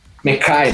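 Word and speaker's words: Mekhi: então eu fico de Mack Becton. Mekai, na verdade, né? A pronúncia do nome dele é...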